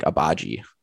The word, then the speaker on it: Agbaji: Ochai Agbaji? – I believe the J is like a harder, like Agbaji.